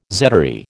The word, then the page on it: zedoary: American English